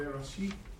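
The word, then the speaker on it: Bereshith (בראשית): Uh, it would be like uh, Bereshith.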